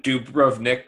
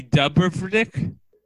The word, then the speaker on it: Dubrovnik: I hate it when Dubrovnik, Croatia, reclaims their bottle openers because there's a picture of Dubrovnik on the back.